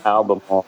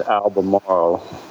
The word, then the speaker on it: Albemarle: Albemarle. I think it's Albemarle. But it often gets an R in there and people misspell it. But there's only one R in Albemarle.